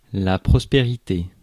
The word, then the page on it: prospérité: [pʁɔs.pe.ʁi.te]; France